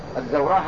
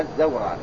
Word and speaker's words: الزوراء: والزوراء في قوله على الزوراء، الزوراء هكذا بهذا اللفظ بفتح الزاي وسكون الواو والراء وراء مفتوحة، يعني الزوراء هكذا كما أنطقه الآن، ما يعني بعدها راء ممدودة، يعني يقول الإمام البخاري هي موضع بسوق المدينة.